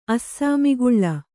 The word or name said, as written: ಅಸ್ಸಾಮಿಗುಳ್ಳ